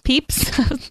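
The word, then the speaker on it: Pepys: On one show, Leo mentioned that if Samuel Pepsis were alive today. – Peeps. – I knew I'd pronounce that wrong. Pepsis. – I never knew how to pronounce Peeps. – It. I may still be saying it wrong, actually.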